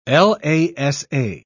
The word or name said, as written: lasa